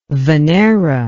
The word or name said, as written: venera